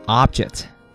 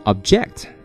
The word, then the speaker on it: object: 我们来看第一个单词叫 object 啊，这个词作为动词呢表反对啊，很多朋友呢可能只知道它作为名词表示物体，那么要注意做名词的时候呢啊，它应该读成 object 啊，重音在低音节上。那么英文当中有一个读音的规律啊，就是如果一个单词既可以做名词，又可以做动词啊，那么名词的重音啊通常在前面，动词的重音在后面啊，所以叫名前动后。所以如果你读成 object，那么它就是一个动词，表示反对的意思。